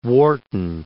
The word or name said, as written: Warton